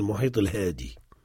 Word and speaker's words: المحيط الهادئ: Mais dans l'usage, on ne prononce pas le hamza et on ne l'écrit pas, on dit le muhit al-hadi.